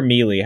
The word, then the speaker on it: melee: Is it melee or melee? How do you say that? – I've always said melee. – Melee? Yeah, I think that's right. – I feel like it could go either way because it's spelled with a bunch of E's.